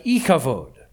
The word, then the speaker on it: Ichabod: That's why she names her son what she does in verse 21, calling him Ichabod. In Hebrew, that's pronounced more like Ichavod.